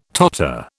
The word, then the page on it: totter: British English